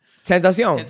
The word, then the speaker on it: Tentacion: Tentacion. – Tentacion. – XXXTentacion. – You can say it with a Spanish accent because it's a Spanish word.